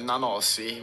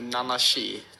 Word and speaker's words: Nanasi: Men hur uttalar du namnet? – Ja, vi säger med Nanasi. Men jag tror på ungerska säger man nu Nanashi, tror jag. – Men Nanasi är det annars.